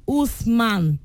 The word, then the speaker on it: Uthman: Because there's one conversation that I have that somebody spell his name as, his name is Huthman. U-T-H-M-A-N, but they say it's pronounced Usman.